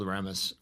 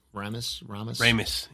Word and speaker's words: Ramis: So, yeah, I think, honestly, for the main three stars, I think even Harold Ramis. – Ramis.